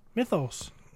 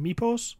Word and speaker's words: mythos: How do you say it? – Mythos. Myth, it's a myth. Mythos. – Mythos. – You say mythos. Sometimes I think you're saying mipos, like from where...